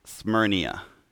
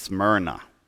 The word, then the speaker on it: Smyrna: I kept, like, as I'm reviewing everything, I kept pronouncing it Smyrnia. I'm like, it has to be an I at the end. But nope, just Smyrna. Kind of like saying your Zoom tight, Smyrna.